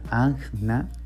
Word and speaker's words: Ajna: Otros chakras, les puedo decir el chakra se pronuncia Ajna.